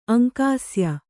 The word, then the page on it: ಅಂಕಾಸ್ಯ: Kannada